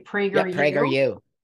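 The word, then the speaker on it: PragerU: Speaking about talking to people, I actually saw the Prager, am I saying it right? Prager U. – Yep.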